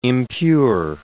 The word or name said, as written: impure